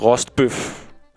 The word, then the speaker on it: rostbøf: Og så håber jeg om ikke andet, at vi kan blive enige om, at det udtales rostbøf herfra og for evigt.